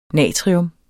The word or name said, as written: natrium